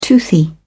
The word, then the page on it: toothy: British English